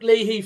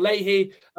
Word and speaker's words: Leahy: A notable mention, I think, at left back, it's very debatable, actually, if you can include him as a left-back, but Luke Leahy for Wickham, or Leahy, if you sort of you want to use the Irish pronunciation.